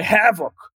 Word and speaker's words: havoc: Eles davam uma ênfase muito grande naquela primeira sílaba, havoc, and let sleep the dogs of war, entendeu? Cry havoc and let sleep the dogs of war. E aí eu pensando nisso, eu me dei conta, claro que isso é um grito de batalha, a sílaba tônica tem que estar tá mais no início, não pode estar tá no fim.